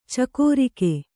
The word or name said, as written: ಚಕ್ರಿಕ